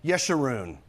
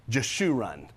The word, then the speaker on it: Yeshurun: Now, that's not Jeshurun, okay? – And I'll tell you, all my years, I've always pronounced it Jeshurun. I learned my lesson this because when I started researching this, there's another pronunciation, the right one.